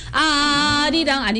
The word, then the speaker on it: 아리랑: 예, 아리랑 두 번째도 똑같습니다. 아리랑 아니고 아리랑 마지막에 꼭 이응 받침을 해주시고요.